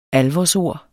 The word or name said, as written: alvorsord